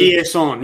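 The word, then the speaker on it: Chiasson: Chieson. Yeah, it's like Chieson. She's from Louisiana, so it's like the French kind of deal.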